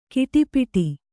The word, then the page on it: ಕಿಟಿಪಿಟಿ: kiṭipiṭi